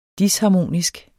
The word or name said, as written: disharmonisk